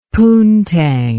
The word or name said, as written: poontang